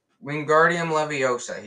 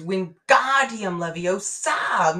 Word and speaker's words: Wingardium Leviosa: When Wingardium Leviosa, he shouted, waving his long arms like a windmill. You're. You're saying it wrong, Harry heard Hermione snap. It's Wingardium Leviosa! Make the ga nice and long.